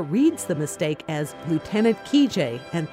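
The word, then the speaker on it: Kijé: Lieutenant Kijet, or Kijet as it's pronounced in Russian, was a book and then a movie about an army officer who never existed.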